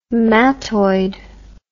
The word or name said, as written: mattoid